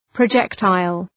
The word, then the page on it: projectile: prə’dʒektıl, prə’dʒek,taıl